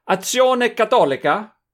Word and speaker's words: Azione Cattolica: There is an Italian Catholic organization called Azione Cattolica. How's that for pronunciation, Andras? – Sounds good to me, yeah.